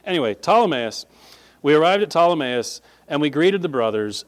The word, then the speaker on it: Ptolemais: When we had finished the voyage from Tyre, we arrived at Ptolemais. Young people looking at that word, don't even try to pronounce that P. It's not really there. It is, but it's not.